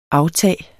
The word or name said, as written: aftag